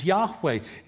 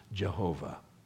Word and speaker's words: Yahweh: It is Yahweh. It is not Jehovah. They don't have a J or a V in the Hebrew alphabet. It would have been impossible for them to pronounce it Jehovah. His name is Yahweh.